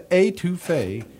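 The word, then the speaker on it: étouffée: And he sings about, and you smell the etouffee. So he pronounces it correctly. If that's how Harry Connick Jr. says it, folks, that's how they say it.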